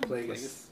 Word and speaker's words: Plagueis: Or I guess it could have been Pelagus, I guess? – Darth Plagueis. – Pelagus. – Did I not say this? Did I say it wrong? – Yeah, you said it weird.